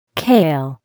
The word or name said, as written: kale